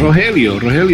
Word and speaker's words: Rogelio: I mean, yeah, what's the right way of pronunciation, your name, your full name? – Rogelio is correct.